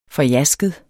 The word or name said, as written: forjasket